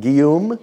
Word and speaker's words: Guillaume: Did I say it right? Guillaume? – Yeah.